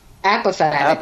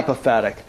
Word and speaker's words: apophatic: Um, uh, correct my pronunciation, but you at some point you talk about the cataphatic and the what was the other one? Apophatic. Apophatic.